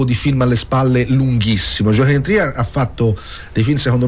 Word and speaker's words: Joachim Trier: di Joachim Trier, ho detto bene? – Sì, è norvegese, mm-hmm, poi hai detto bene,